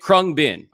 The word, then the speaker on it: Khruangbin: And what's wild about them, and I watched like two or three, Krungbin. That's how you say it, Krungbin.